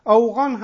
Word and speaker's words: افغان: بله، اوغان هم یکی از تلفظهای کلمه افغان هست.